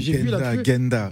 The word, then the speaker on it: Ghenda: Gwenda qui sont donc euh, avec nous. J'ai bien prononcé, j'espère que j'ai pas massacré. J'ai Genda.